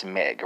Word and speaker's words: mig: Well, that's Meg, right? But it's not spelled as in Meg, like, you know, the common English name for women. It's spelled M-I-G, right?